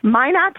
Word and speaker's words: Minot: Minot, North Dakota.